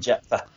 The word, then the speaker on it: Jephthah: And Jephthah, I can't ever say his name. – Jephthah. – Yes, thank you.